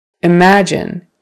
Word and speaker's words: imagine: Imagine. Imagine. Now it's on the second syllable.